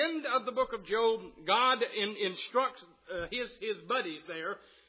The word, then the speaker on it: Job: No, it is not pronounced Job. Pronounce it right, Job. And that is Job.